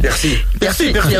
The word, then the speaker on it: Piercy: Mujer al borde del tiempo, de Marge Piercy, o Piercy, no sé muy bien cómo se pronuncia. – Piercy. Sí, muy rápido.